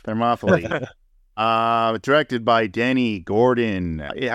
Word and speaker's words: Thermopylae: Thermopylae. That's how they say it, yeah. I think it's supposed to be Thermopylae.